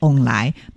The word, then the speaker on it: 凤梨: 对，除此之外呢，其实啊，还有就是我们在台语当中的凤梨啊，其他的读音叫做翁来，那翁来呢就是有好运旺旺来的意思，因此呢在过年啦或者是想要送礼的话，或者是祝贺别人的时候呢，也会把凤梨酥呢当作是一个伴手礼啊，就是诶有祝福人家的意思。